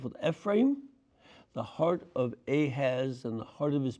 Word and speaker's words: Ahaz: That's how you say it in Hebrew, Ahaz.